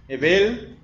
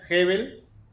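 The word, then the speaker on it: hevel: ¿Cómo? Ebel, Ebel, o algunos lo pronuncian Hebel, quiere decir, bueno, es la, esta es la palabra que se ha traducido a nuestro idioma como vanidad.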